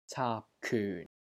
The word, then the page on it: CAAP3 KYUN4: Cantonese